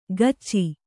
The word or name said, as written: ಕಚ್ಚಿ